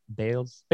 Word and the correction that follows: Bayless: Bayless. – Bayless. Okay.